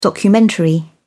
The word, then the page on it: documentary: British English